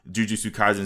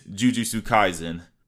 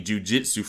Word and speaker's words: Jujutsu Kaisen: So, what is Jujutsu Kaisen? Well, first off, the pronunciation is kind of hard itself. I kept saying jujitsu for like the first couple weeks, but I can't lie to y'all.